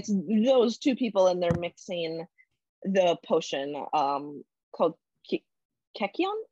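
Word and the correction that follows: kykeon: Hold on, I have how to pronounce it in my notes. – Kikion, yeah, thank you. Okay. So yeah, it's Kikion.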